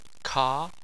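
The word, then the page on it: ka: tone 2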